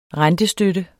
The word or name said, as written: rentestøtte